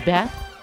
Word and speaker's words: Beth: My name is Beth, but it sounds like Biff, so I answer to both.